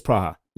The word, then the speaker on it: Praha: It says Praha at the top of that board. – Yeah. – So that's how they say it? – Yeah. – Okay. All right. So Prague is Praha.